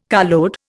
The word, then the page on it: carload: Indian